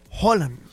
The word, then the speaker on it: Haaland: Lo dice, la H se aspira como una J muy suave en español, similar al inglés. Y la doble A noruega suena como una O abierta. Por lo tanto, se pronuncia Holland. Evitando la D al final.